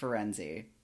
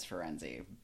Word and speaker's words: Firenze: It's a city in Italy, which is pronounced Firenze, which is how I've always said it. If you ask the internet how to say it, like the word itself, because it's a real word, it says Firenze.